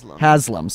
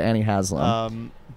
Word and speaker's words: Annie Haslam: So he was in this relationship with Annie Halstam. – Yeah. – Am I pronouncing that right? – Haslam. – Sorry, Annie Haslam.